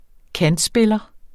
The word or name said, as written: kantspiller